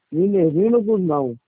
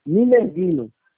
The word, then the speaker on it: Minervino: Qual o nome? – Minervino Guzmão. – Guilhermino Guzmão? – Minervino. – Como é que se escreve? – M-I. – N-I. – M. – M de mamãe, M-I. – N-E-R. – N-E. Uhum. – V-I. – Minervino Guzmão. – Isso.